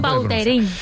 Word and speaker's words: Vautrin: Es que a mí me suena mejor Vautrin. Es como... – Daros fuerza, ¿no? – Más puede ser más la pronunciación. – Vautrin. Sí. Elegante. – La original francesa, Vautrin. – Vautrin.